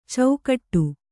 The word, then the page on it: ಚೌಕಟ್ಟು: caukaṭṭu